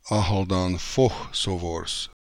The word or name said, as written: Akhaldanfokhsovors